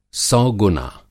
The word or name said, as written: सौ गुना